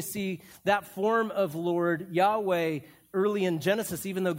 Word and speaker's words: Yahweh: If you see any time where it's L-O-R-D, lowercase, that's Adonai. When it's capitalized like that, it's Yahweh or Jehovah is another way of pronouncing that.